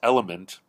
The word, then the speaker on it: LMNT: But to me, my cardiologist recommended a brand called LMNT, like the letters LMNT, which is pronounced element, from Elemental Labs, and it's called Recharge Electrolyte Drink Mix.